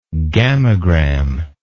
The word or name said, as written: gammagram